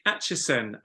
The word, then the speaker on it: Acheson: My name is Nick Acheson and I am a conservationist. So yes, I see you smile there. That is the correct pronunciation.